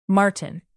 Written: martin

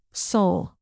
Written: soul

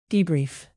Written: debrief

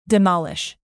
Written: demolish

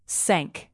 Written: sank

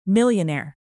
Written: millionaire

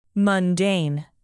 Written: mundane

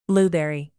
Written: blueberry

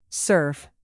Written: serve

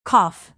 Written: cough

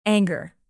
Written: anger

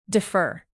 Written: defer

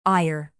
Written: ire

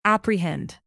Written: apprehend

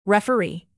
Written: referee

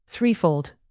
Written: threefold

